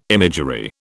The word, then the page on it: imagery: British English